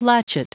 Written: latchet

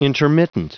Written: intermittent

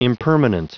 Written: impermanent

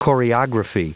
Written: choreography

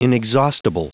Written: inexhaustible

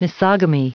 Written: misogamy

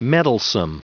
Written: meddlesome

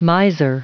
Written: miser

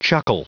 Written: chuckle

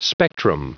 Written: spectrum

